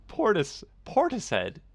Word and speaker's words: Portishead: Portishead? Like P-O-R... rhymes with tortoise? – Sort of, yeah. Tortoise. Yeah, it does rhyme with tortoise.